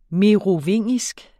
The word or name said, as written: merovingisk